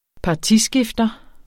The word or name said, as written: partiskifter